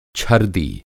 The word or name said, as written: छर्दि